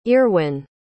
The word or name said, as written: earwyn